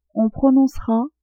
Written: on prononcera

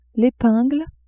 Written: epingle

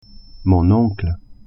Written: oncle

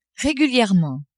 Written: régulièrement

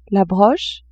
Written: broche

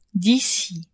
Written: d'ici

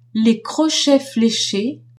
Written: crochets fléchés

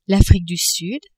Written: Afrique du Sud

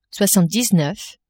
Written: soixante-dix-neuf

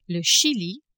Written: Chili

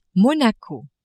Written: Monaco

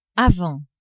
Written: avant

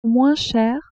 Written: moins cher

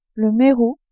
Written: merou

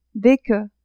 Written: des que

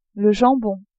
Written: jambon

